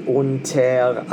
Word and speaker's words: unter: Unter is really accented on the first syllable, but because the music pushes us the other way, you have to accent it a little bit on the other.